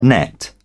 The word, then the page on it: nett: British English